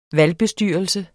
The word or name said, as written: valgbestyrelse